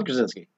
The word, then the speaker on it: Krasinski: Yeah, John Krasinski.